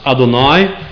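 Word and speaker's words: Adonai (אֲדֹנָי): It's normally pronounced Adonai.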